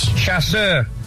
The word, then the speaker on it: chasseur: A chicken chaucer. – A chicken chaucer. – Chicken chaucer. – How do you pronounce it? – Chasseur. Chicken chasseur. It's a French sauce.